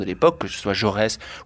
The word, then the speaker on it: Jaurès: And of course, there were those great leaders like Jaurès here. Is that how you pronounce it? – Jaurès.